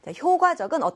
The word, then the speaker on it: 효과적: 어떻게 읽는다고요? 효과적이 아니라 효과적이라고 읽는 겁니다. 가끔가다 기역, 디귿, 비읍, 시옷, 지읒 같은 이 예사 소리를요. 마음대로 된소리로 발음하는 경우들이 있어요. 발음하는 친구들이 있습니다. 실수하는 경우가. 그래서 마찬가지로 이 낱말도 효과적 이렇게 읽는 친구들이 간혹하다. 이딴 뜻이죠. 그런데 그것이 아니라 효과적, 효과적으로 표현하기 위해서는 이렇게 읽어야 한다는 것입니다.